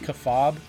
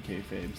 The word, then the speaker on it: kayfabe: Isn't it pronounced kafab, or is it kayfabe? – I've never heard it pronounced kafab. – Maybe it is kayfabe, and I've just been... – I've heard Mike Falzone pronounce it kayfabe, so...